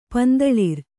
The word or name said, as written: ಪಂದಳಿರ್